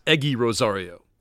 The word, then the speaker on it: Egy Rosario: It's spelled Egy Rosario. – Yep. – And it's pronounced Egy Rosario.